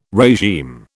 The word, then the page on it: regime: British English